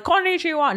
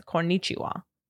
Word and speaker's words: konnichiwa: So it doesn't go like up and down. It's not like konnichiwa. No, it's konnichiwa.